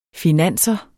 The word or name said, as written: finanser